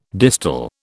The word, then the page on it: distal: British English